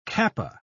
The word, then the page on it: kappa: British English